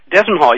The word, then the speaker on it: Dezemhall: Dezemhall, yeah, that sounded close to me.